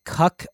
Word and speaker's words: Kuk: So in this clip, we're going to listen from legendary vocal producer and songwriter Cuck Harrell, and that is spelled K-U-K. I think it's actually a nickname. But Cuck Harrell, he's won Grammys.